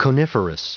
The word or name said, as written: coniferous